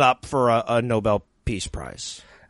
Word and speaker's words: Nobel: Okay, first of all, it's pronounced Nobel. – Are you sure? – I heard it was Noble.